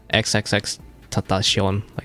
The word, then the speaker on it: XXXTentacion: I was looking to see if I had any pictures with him, but yeah, I knew X-Tentation. Jose. – Yeah, it's pronounced XXXTentacion, Spanish, I guess.